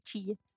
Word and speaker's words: Chia: Se escreve Chia, aquela semente mesmo, mas se lê Chia.